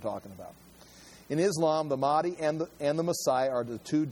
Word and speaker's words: Mahdi: And again, I've got pronunciation there, Mahdi.